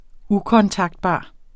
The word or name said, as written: ukontaktbar